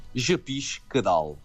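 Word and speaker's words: je pige que dalle: je piche que dalle.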